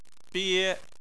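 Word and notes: bie: tone 1

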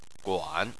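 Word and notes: guan3: tone 3

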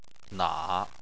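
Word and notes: na: tone 3

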